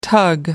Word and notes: tug: American English

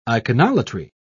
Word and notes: iconolatry: British English

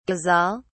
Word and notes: ghazal: British English

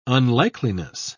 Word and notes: unlikeliness: British English

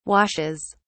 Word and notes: washes: British English

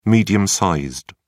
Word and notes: medium-sized: British English

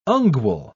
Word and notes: ungual: British English

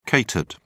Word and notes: catered: British English

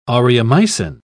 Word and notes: aureomycin: British English

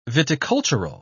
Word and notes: viticultural: British English

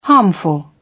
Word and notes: harmful: British English

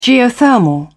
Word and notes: geothermal: British English

